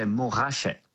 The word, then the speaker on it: Montrachet: Montrachet, Pouligny Montrachet, which actually in French, we don't pronounce the T, we say Montrachet.